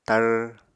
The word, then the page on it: tar: Apatani